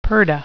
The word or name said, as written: purdah